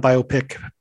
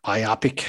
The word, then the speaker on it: biopic: not the Elton John biopic, but biopic. I guess I've been saying that wrong for the last few years, but Harlan Williams did a movie called Rocket Man where he goes into space, and I'm sure it's quite hilarious.